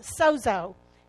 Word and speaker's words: sozo: The root word for salvation is a Greek word pronounced sozo, S-O-Z-O.